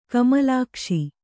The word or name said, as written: ಕಮಲಾಕ್ಷಿ